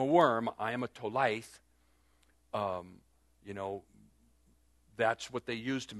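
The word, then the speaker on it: tolaith (תולעת): It comes from, the worm is, it's spelled in Hebrew, T-O-L-A-I-T-H, tolaith.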